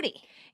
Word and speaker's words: Schuette: Schuette. It's not spelled that way, but it's pronounced that way.